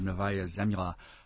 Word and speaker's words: Novaya Zemlya: in Pechora Sea near the island of Novaya Zemlya.